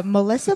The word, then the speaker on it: Melissa: Oh, Melissa Polinar, M-E-L-I-S-S-A. My last name, Polinar, P-O-L-I-N-A-R, rhymes with pollination, kind of, not really.